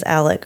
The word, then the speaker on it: Alex: And his name is spelled A-L-E-X, like Alex, but they pronounce it, and he pronounces it himself as Alec or Alec.